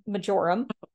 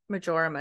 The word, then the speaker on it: marjoram: And those players are thyme, oregano's hot cousin marjoram. – Marjoram is how I pronounce it, but I've heard marjoram as well.